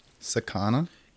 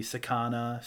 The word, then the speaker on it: sakana: I'll spell it how it's spelled on the webpage. It's S-A-K-A-N-A. So it could be sakana, sakana.